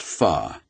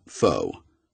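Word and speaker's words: phở: It's pronounced pho, not pho.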